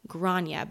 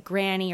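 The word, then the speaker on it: Gráinne: Grania, but it's spelled G-R-A-I-N-N-E. And this has led to many different spellings and pronunciations of her name. Of course, people thought it was like granny or grainy, but we're going to call her Grace because that is the American or the English translation.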